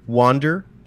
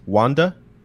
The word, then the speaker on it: Wander: Um, and you see the main character wander in japanese it's wanda uh, kind of stroll in on a horse carrying a maiden he wanda.